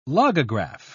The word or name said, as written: logograph